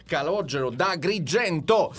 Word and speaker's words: Calogero da Agrigento: Sì, però forse hai sbagliato la pronuncia. – Perché, come si chiama? – Calogero di Agrigento. – No, è da Agrigento con la da Grigendo.